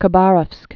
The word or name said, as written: Khabarovsk